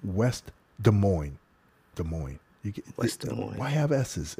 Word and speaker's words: Des Moines: Do you know the S is silent in Des Moines? – I did not know that. – Dude, I said to the guy, I was like, Des Moines.